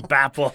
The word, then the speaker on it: Bhopal: I was like, Bapal. Bhopal.